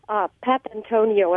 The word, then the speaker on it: Papantonio: It's Papantonio, and I think it's spelled P-A-P-A-N-T-O-N-I-O.